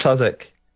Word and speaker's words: Southwark: it's pronounced Southwark Crown Court. It's spelt Southwark for anyone who isn't familiar with the English way of pronouncing things.